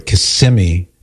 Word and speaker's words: Kissimmee: Kissimmee. Not Kissimmee, Cyrus.